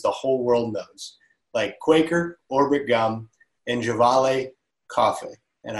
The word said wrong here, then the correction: Gevalia: Um, first of all, I, I would, it's Gevalia Cafe, but that's okay. Um, everybody mispronounces it, and um, even internal people at, at Kraft used to.